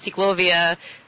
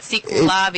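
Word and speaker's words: CicLAvia: way that LA jumps out in the middle there, but yeah, in terms of pronunciation, sometimes Cyclovia, Cyclavia, it's a little hard to hear that difference, but Cyclavia yeah. – is what we're having tomorrow.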